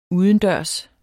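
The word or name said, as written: udendørs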